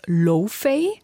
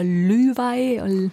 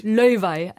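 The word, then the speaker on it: Laufey: Also zuerst dachte ich, sie heißt Lofay, weil man es so vielleicht englisch aussprechen würde. Aber ich habe mir sagen lassen, es wird Löwei oder Lüwei. – Löwei. – Also genau. Isländisch.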